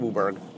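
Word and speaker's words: WUBRG: We pronounce it Wuburg.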